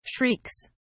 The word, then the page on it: shrieks: British English